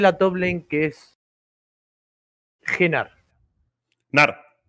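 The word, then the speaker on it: Gnar: Sí, Nar. Perfecto. Se le va a dar como correcta porque en el habla inglesa la G N no se convierte en ñ, no sería ñar.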